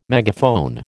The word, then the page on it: megaphone: American English